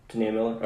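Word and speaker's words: T'Nia Miller: And I don't know how to pronounce her name. It's T apostrophe Nia Miller. – Tania Miller.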